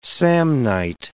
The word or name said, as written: Samnite